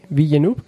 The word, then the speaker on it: Villeneuve: Actualmente, tras la salida de Ridley Scott, Denis Villeneuve, ¿cómo se pronuncia este güey? Villeneuve, tomó el cargo de director de este clásico de la sci-fi.